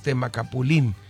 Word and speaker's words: Temacapulín: Tiene acento en la I, entonces Temacapulín.